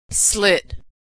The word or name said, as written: slit